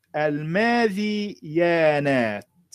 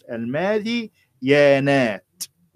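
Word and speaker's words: الماذيانات: الماذي يانات بذا المكسورة ثم ياء فألف ثم نون فألف ثم تاء هذا هو المشهور في نطق هذه الكلمة الماذي بكسر الذال الماذي يانات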